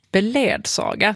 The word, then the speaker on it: beledsaga: Beledsaga. Där anger de faktiskt det första uttal, normaluttag, som beledsaga.